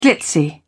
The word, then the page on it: glitzy: British English